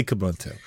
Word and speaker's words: Kubuntu: Now, through all of this Ubuntu jumping we've been doing, have you played with Kubuntu? Is that how you pronounce it, or is it like Zeus or something? – It's, you never know. – It's totally Kubuntu.